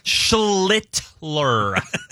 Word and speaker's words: Schlittler: Schlittler. I mean, very much emphasize the pronunciation there. – You got it that time.